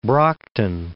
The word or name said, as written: Brockton